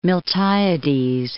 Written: Miltiades

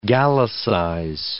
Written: Gallicize

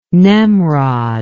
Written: nemrod

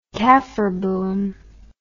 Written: kaffir boom

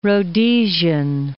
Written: Rhodesian

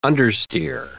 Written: understeer

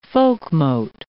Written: folkmote

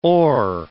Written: ore

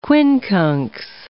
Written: quincunx

